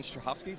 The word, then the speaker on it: Strahovski: Hey, Geekscapists, and I'm here with Yvonne Strahovski. Did I do it right? – Yes, did I say it right? – Yeah.